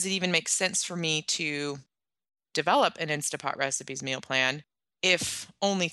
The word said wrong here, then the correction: Instant Pot: Sorry, I'm not saying that right. Instant Pot.